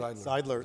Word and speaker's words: Zeidler: So one distinction I'd make is, you know, when I look at Ontario Place and I look at, you know, is it Zeidler? Did I say it right? – Zeidler, the pods.